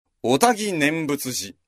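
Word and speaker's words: 愛宕念仏寺: これ難しいですね、読みが。あたごと書いて、おたぎ、おたぎと読む。おたぎ念仏寺。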